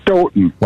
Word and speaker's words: Stoughton: You actually, the second time you said it right, Stoughton. They pronounce okay. it Stoughton. – Like Houghton, Michigan.